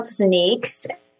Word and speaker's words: ZNEEX: It's called ZNEEX.